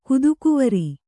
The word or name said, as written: ಕುದುಕುವರಿ